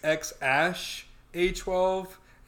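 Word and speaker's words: X Æ A-12: Elon is saying it's X Ash A 12, and A 12 stands for the Archangel 12, which is like a fighter jet.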